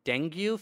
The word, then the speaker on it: dengue: dengue fever, is that how you say it? – Uh-huh.